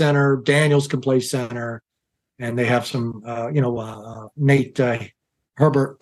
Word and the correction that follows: Herbig: can play center. – Yeah. – Did I say that right? – Herbig.